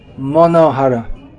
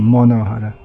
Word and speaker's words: Manohara: Okay, name 461 in the Sri Vaishnava commentary on Sri Vishnu Sahasranama is Manohara, Bengali pronunciation, Monohara, which is easy to understand.